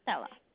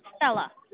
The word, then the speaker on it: Stella: Sure, my name is Stella. – Phyllis? – Stella. – Spell that. – S-T-E-L-L-A.